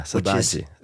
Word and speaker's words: saudade: saudade. – Oh, saudade. Yeah, saudade. It's such a good word. – Such a beauty. And I guess it's really hard to really translate. – Yeah, saudade is,